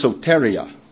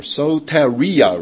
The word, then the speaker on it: soteria: It is pronounced soteria, or soteria, rather.